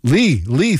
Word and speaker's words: Leigh: Got something from Boy Genius on the way, some Death Cab for Cutie, and hey, is that Lay? Is that Lay? L-E-I-G-H. How do you say that? – Lee, thank you.